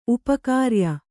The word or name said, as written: ಉಪಕಾರ್ಯ